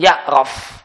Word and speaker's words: ya'raf: Berdasarkan sabda Nabi kepada Fatimah binti Abi Hubaysh, dia mengatakan, Nabi mengatakan, jika darah haid, fa'innahu aswad yu'raf, atau fa'innahu aswadun ya'raf. Ada dua cara baca. Dua-duanya benar. Maka jika darah adalah darah haid, maka dia hitam.